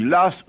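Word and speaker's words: Law: Doit-on prononcer aujourd'hui l'As? Selon les érudits qui ont étudié cette question, on devrait en effet le prononcer l'As, puisque c'est ainsi qu'on le prononçait à l'époque.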